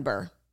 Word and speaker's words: Bure: We also have been pronouncing her name wrong. It's Candace Cameron Bure.